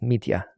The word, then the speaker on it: Mitya: So they're all kind of Russian pronunciations. It's Mitya.